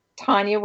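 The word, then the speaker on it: Tonja: I'm on Facebook and my name is spelled really strange. It's Tanya Waring, TonjaWaring.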